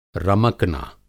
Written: रमकना

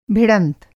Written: भिड़ंत

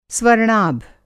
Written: स्वर्णाभ

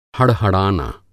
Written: हड़हड़ाना

